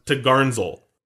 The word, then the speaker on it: topgallant sail: And then the topgallant sail, or t'gallant sail, is pronounced t'garnsel. I don't know, maybe I butchered it all.